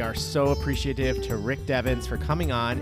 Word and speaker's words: Devens: It's pronounced Devens.